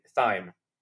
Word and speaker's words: thyme: And then, oh, the most important thing thyme. Is that how you say it? Thyme. Yeah, thyme.